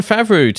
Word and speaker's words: Favreau: This allows Jon Favreau to...